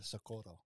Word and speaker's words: Socorro: Or Socorro. Oh, look yeah. at you! Look at you! You have to say it like that. There, you get shot. I saw two people go down for saying it the wrong way.